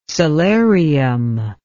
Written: solarium

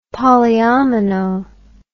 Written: polyomino

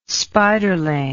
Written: spiderling

